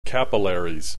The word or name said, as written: capillaries